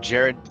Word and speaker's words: Jerad: You can follow me at Jared at Jared Chill, J-E-R-A-D, is spelled, you know, a little backwards, but C-H-I-L.